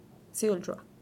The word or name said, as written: zuillrua